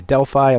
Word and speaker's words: Delphi: Other people say Delphi. I'm going to say Delphi for tonight. It's spelled D-E-L-P-H-I.